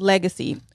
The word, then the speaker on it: Legase: It's spelled different. So if you guys see it, it's LEGASE. And it's pronounced Legacy.